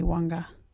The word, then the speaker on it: Wangga: My little friends are learning Wagyu Wanga. Wanga is spelt W-A-N-G-G-A.